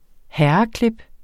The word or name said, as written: herreklip